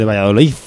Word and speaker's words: Valladolid: Es de Valladolid, con vale. Z. Efectivamente, un castellano de pro jamás pronunciaría una D bien al final de la palabra.